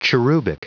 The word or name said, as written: cherubic